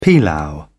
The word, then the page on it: pilau: British English